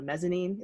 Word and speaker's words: mezzanine: Is what it's called, a mezzanine. Is that how you say it? – Yeah.